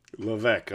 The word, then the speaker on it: Levesque: You're close. The S is silent. – Okay, so say it for me. – Levesque. Maybe. Or if you're a good Frenchman here in Canada, it's Levesque. Levesque is just fine.